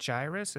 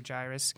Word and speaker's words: Jairus: Is it Jairus? Is that how you say it? Yeah, I believe Jairus.